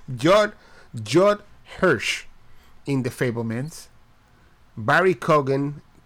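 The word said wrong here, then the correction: Keoghan: How do you say that? Keegan? – Yeah, I gotta say with like an Irish accent. I don't know how to do one. But Barry Keegan? – Yeah, kind of like there that.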